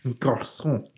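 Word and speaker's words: garçon: Garcon. – Oh, yeah. – I don't think I did that quite right. – Criminy. – Definitely a soft C.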